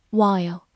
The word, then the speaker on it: while: While, W H I L E, while, W H 发 w，字母 I 发的本身的音 I。 l 有个滑音，末尾的 e 不发音。